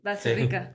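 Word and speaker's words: Bazterrica: Basterrica.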